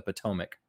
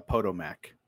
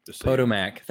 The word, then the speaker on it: Potomac: She said that he stood at a window of the Oval Office, hands clasped behind his back, gazing out over the Potomac. Uh, perhaps he was still seeing the bloody battlefields beyond. Pondering Abe, uh, looking out the I, window. Uh, I believe that's pronounced uh, Potomac. – Potomac.